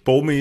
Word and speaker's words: Bomi: Die Frage ist natürlich, vielleicht spricht man die Mutter Yeah aus. Aber bei Bomi bin ich mir sehr sicher. – Bomi ist klar, Bomi.